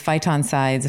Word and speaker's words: phytoncides: these phytoncides, if I'm pronouncing it properly. – Yeah, correct.